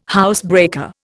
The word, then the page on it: housebreaker: Indian